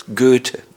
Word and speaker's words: Goethe: Goethe.